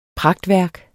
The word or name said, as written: pragtværk